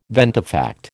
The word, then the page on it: ventifact: American English